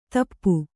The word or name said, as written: ಧೂಪ